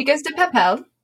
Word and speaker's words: chicas de papel: We're going to do chicas de papel. Mm-hmm. Did I say that right? Chicas de papel? – Mm-hmm. Yeah, you did.